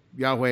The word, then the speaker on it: Yahweh: We conjecture Yahweh, since there are no vowel points from the Masoretes on how to say this word. And so Yahweh is the Hebrew scholarship best answer, Yahweh or Yahweh, on the pronunciation of the sacred four-letter name of God presented in Exodus 3.